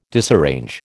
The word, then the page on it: disarrange: American English